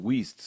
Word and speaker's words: Weist: Well, you know, my name gets Weist and Worst and West and all. It's pronounced Weist, so... And it's a German spelling, so I'm real.